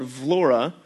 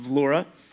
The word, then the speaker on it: Vlora: So we're praying about serving in a southwestern coastal city called Vlora. So you can see it down at the bottom. It looks like it's pronounced Vlor, but it's pronounced Vlora.